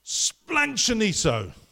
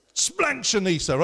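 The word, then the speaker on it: splanchnizomai: Splanchoniso. Splanchoniso, right? You've almost got to kind of sneeze it out of you, right? Splanchoniso.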